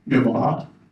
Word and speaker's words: Yahweh: In Hebrew, we would pronounce it Yavah. We translate it into English as Yahweh.